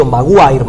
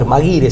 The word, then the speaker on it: Maguire: Y también, eh, a principios de los 80, un periodista argentino, escritor, ensayista, José Patricio Maguire, Maguire se escribe, pero es un apellido irlandés,